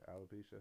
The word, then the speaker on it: alopecia: Did I say that right? Alopecia? – Alopecia. – Yeah, alopecia.